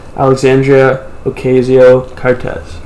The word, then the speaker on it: Alexandria Ocasio-Cortez: Big time, Alexandria Ocasio-Cortez.